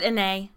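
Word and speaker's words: Anet: In the lands of Annette, or Annay. – Is it Annay? I guess it would be. Because the T is silent in French. – Just, if you're doing your own research, it's A-N-E-T, like Annette.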